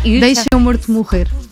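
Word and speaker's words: Deixem o Morto Morrer: There is a track called Deixemo Morto Morrer. Am I pronouncing that? Am I butchering that pronunciation? – It's in Portuguese. It's very hard to pronounce Portuguese, but it's almost perfect.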